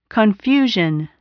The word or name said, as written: confusion